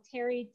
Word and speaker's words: Tari: Now, my name is spelled Tari,